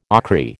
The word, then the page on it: ochry: American English